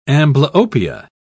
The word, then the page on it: amblyopia: British English